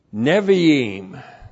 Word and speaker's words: Nevi'im: So point one, Zechariah is a part of the Hebrew canon known as the Nevi'im. That's N, Shiva, B, long I, Aleph, long I, M. Nevi'im.